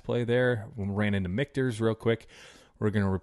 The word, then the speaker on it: Michter's: Said it right. Mark the tape. 33 minutes and 47 seconds. Said Michter's correctly.